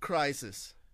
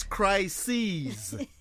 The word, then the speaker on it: crises: I'm a crisis. – It's crises. – Yeah. – And how do you spell it? – Because it's plural. – Yeah. – Crises. You spell it C-R-I-S-E-S. C-R-I-S-E-S. Crises. Okay. There's a crisis and then the crises.